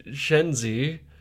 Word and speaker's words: Czenzi: C-Z-E-N-Z-I. Chenzi. – Chenzi? – Chenzi? – I assume Zenzi? So. – I heard it out loud in some of the behind-the-scenes stuff. Oh. I was like, oh, it's like that. It was just like a very common pronunciation. – Like Chardis. Chenzi.